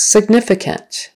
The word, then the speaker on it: significant: Significant. Second syllable stress. Significant.